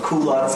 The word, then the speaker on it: culotte: Well, and they pronounce it, it might be French originally, but American people say culotte, cool, like not Q, but. In Japanese, it's kyudo or something, right? But in America, we say ku.